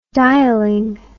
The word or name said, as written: dialing